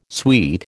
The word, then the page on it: sweet: American English